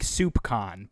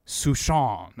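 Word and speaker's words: soupçon: It looks like soup con, but it's not. It's like Souchon or something like that.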